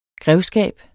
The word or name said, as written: grevskab